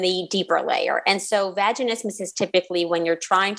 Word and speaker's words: vaginismus: One of the things we were talking about at the break that we're going to get right into is vaginismus. Did I just say it right? – You said it perfectly.